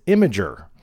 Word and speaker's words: Imgur: Now, there's a lot of ways to say it. The correct pronunciation is Imgur.